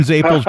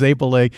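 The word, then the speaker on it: zeppole: I call them Zapels because that's how they pronounce it in Rhode Island. I know it's Zapele in Sicily, but yeah, bakery that does Zapels in St. Louis.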